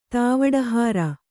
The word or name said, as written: ತಾವಡಹಾರ